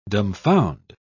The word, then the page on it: dumbfound: British English